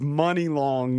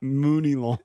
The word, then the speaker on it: Money Long: And apparently the rapper is Money Long, not Mooney Long.